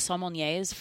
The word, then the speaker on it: sommelier: Do they have like sommeliers for? Is that how you say it? I may be saying it right. Sommelier. – Yeah.